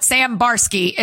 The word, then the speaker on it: Sam Barsky: Sam Barsky is exactly how you think it is. That's what I meant. – I see. – Like a bar, a ski.